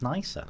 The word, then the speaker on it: Nisa: But I would say something, NISA say it should be pronounced nicer.